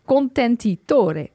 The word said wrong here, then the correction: contenitore: Contenitore. Coperchio contenitore.